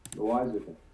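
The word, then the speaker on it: Loáisiga: Jonathan Losiga? Siga? – Loisica. – Loisica. – Spell? – L-O-A.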